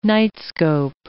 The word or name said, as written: nightscope